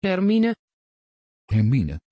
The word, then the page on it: hermine: Dutch